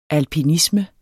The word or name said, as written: alpinisme